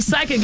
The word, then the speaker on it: psychic: So you're right, because when we see psychic, it's spelled P-S-Y, right? – I couldn't tell you. – Yes, yeah. – So the P is silent.